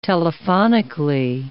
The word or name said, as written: telephonically